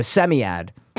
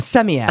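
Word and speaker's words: psammead: It's P-S-A-M-M-E-A-D, but the P is silent. So it's a semiad. A semiad.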